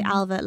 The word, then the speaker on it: Ailbhe: So if I say Alva, like A-L-V-A, that's the easy way to remember it.